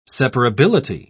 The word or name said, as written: separability